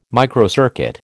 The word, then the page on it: microcircuit: American English